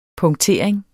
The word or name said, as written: punktering